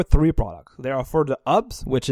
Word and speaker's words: HOOBS: Hoobs, H-O-O-B-S. – Hoobs.